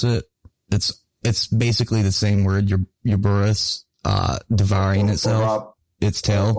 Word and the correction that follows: Ouroboros: Ouroboros or Ouroboros.